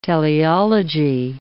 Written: teleology

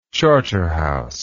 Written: charterhouse

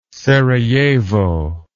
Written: serajevo

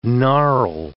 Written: gnarl

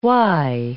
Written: why

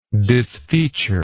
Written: disfeaturement

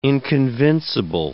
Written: inconvincible

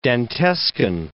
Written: Dantescan

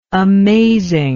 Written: amazing

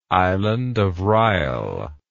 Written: island of reil

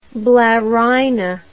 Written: blarina

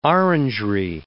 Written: orangery

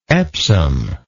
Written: epsom

